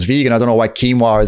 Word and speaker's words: quinoa: I should have said it your way, so you followed quinoa. Yeah, like Joaquin, but the other way around, quinoa.